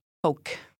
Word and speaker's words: poque: P O Q U E. You don't pronounce the E. Poke.